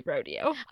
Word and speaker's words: Rodeo: Like, No, locals don't pronounce it that way. Don't you know? It's definitely Rodeo.